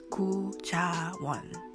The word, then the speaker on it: Gu Ja Won: Oh, and by the way, I'm introduced on the show as Gu Ja Won. That's how you say my name in Korean. The last name comes first in Asian culture, Gu, and then Ja Won.